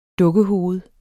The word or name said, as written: dukkehoved